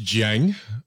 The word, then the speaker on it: Giang: The last name is pronounced Jiang.